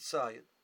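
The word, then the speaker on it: Tzayid (צידה): Betzudalitzayid is, it's written Tzadi Yud Dalet Hey, and the Hey is not pronounced, so it's a Kri, and a Ksiv. The Ksiv is Tzedo, Tzadi Yud Dalet Hey. We pronounce it Tzayid.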